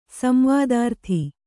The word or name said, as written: ಸಂವಾದಾರ್ಥಿ